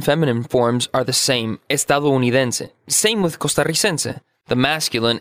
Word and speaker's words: estadounidense: Okay, so estadounidense is American.